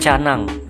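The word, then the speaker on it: Tjanang: Itu ada es krim canang. Kalau sobat BPJ tahu itu sebetulnya nanang sih ya, tapi tulisannya ejaan TJA.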